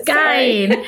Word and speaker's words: skein: It's a skein. – But all the knitwear knitters, the hand knitters, they all know what skein is. So, Of course they do. I knew what it was when I saw it. I just was pronouncing it wrong.